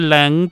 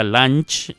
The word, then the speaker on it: Lange: Se dice Lang para los que dicen Jessica Lange, no se dice Jessica Lange.